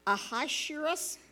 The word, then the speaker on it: Ahasuerus: So the king is Ahasuerus. That's what my husband said to pronounce it, because I sure had no way of knowing.